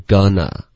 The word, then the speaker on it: gonna: 好后面 going to 的这个将来时的这个发音是 gonna. Gonna. 然